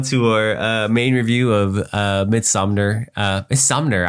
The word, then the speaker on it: Midsommar: Midsommar. – Yeah, there's no N, so it's just Midsommar.